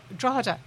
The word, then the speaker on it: Drogheda: Drogheda.